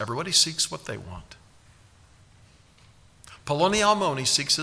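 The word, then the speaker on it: Polonialmoni (פלוני אלמוני): That's exactly how you pronounce it. Polonialmoni.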